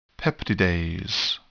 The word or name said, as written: peptidase